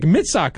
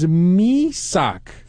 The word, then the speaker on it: Misak: And, during the show, I was like, his name is Misak. And Tim actually got really embarrassed, and he was like, oh, is it really? How do you say it? Misak.